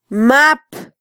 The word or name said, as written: map